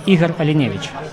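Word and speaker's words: Ihar Alinevich: Scusate, è il primo, quindi Ivar Almivavich. Can you help me with the right pronunciation? Sto chiedendo se mi aiuta con la giusta pronuncia, perché... – Eh sì, Igor Alinevich.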